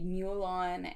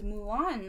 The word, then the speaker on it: Mulan: By both of them, he means both of the wrong ways in which we hear people pronounce this title, which would be Mulan and Mulan, which pains me to say out loud. But yes, this is Mulan, 1998.